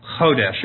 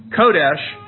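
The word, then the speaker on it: kodesh (חודש): The word for new moon in the Bible is a Hebrew word, and it's one Hebrew word, and that Hebrew word is kodesh, or chodesh, if you want to pronounce it like Christian. You say kodesh,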